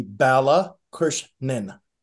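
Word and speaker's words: Balakrishnan: And then Sid, I always have a hard time pronouncing your last name. Can you pronounce that for us? – I think we did it on the first one as well. – Balak. – It's going to be Bala Krishnan, Sid Bala Krishnan.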